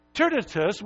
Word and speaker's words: Tertius: I think that's the way you say his name. It's T-E-R-T-I-U-S. I think it's Tertius. That's close enough, right?